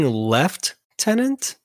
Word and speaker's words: Lieutenant: I think another pronunciation for it. I think that might be more British. Is left tenant.